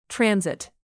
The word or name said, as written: transit